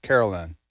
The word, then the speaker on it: Karoline: She spells her name K-A-R-O-L-I-N-E, Levitt. Mm-hmm. She pronounces it Caroline.